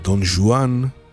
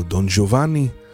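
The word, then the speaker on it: Don Juan: דון חואן, שנקרא גם דון ז'ואן, על פי הגייה ספרדית קדומה, או דון ג'ובאני, על פי הצורה האיטלקית, זה דמות דמיונית שהמקור שלה זה כנראה בסיפור עם, והעלילות שלה מתוארות על ידי סופרים רבים בגרסאות שונות.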